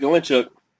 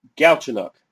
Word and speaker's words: Galchenyuk: And then for Paquette and Galinchuk, Galchenyuk.